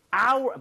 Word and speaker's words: hour: H는 자음이면 어잖아요. – 하지만 묵음이죠. – 그죠. 이게 hour, 묵음이 아니라 예. 네. – hour 이렇게 발음이 되는 거예요. – 아. – 발음 자체가 모음 사운드예요.